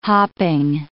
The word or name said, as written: hopping